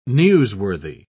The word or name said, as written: newsworthy